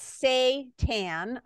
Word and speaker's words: seitan: by the way, it's spelled S-E-I-T-A-N, and you pronounce it like S-A-Y-T-A-N, it's Satan, okay?